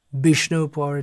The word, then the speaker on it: Vishnupur: There are so many places, Vishnupur in Bengal, Vishnupur it's pronounced.